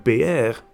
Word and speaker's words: peer: Er bestaat veel verwarring over de uitspraak van het woord peer. De meeste mensen spreken het uit als peer, maar de laatste tijd is de uitspraak PR met de klemtoon op de tweede E steeds vaker te horen. Op die manier zou het worden uitgesproken in het gebied waar peren oorspronkelijk vandaan komen.